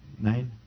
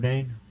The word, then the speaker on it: Nain: Did I pronounce it correct, Pastor? Nain? The widow of Nain? Thank you.